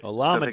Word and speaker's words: Olamide: So the way you pronounce his name, it's better learn it. – O, like O-H, Llama, as in like the animal, Day. – So it's like a Llama Day. Day.